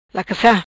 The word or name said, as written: Lacassa